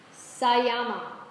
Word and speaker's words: Samyama: And united, these extraordinary supernormal powers, together, they are called Sayama. And it's spelled S-A-M-Y-A-M-A, but it's Sayama. So let's all practice that. Sayama. Very good. So there's no, it's not Samyama. It's Sayama. And you get a choice.